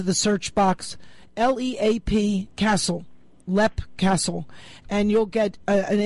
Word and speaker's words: Leap: A fun thing from there is you leave the grounds, you make a right turn until you come to Lepp Castle, which is spelled like Leap, L-E-A-P, but it's pronounced Lepp.